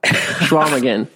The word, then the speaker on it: Chequamegon: Schwamigan, yeah.